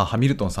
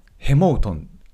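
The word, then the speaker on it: Hamilton: ハミルトンさんですねってなっちゃうけど発音的にはやっぱヘモートンヘモートンって言った方が通じる、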